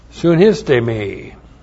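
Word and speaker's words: Sunistemi (συνίστημι): S U N I S T long E M I. Sunistemi.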